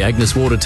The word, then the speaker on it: Agnes Water: Agnes Waters? – Mate, it's pronounced Agnes Water.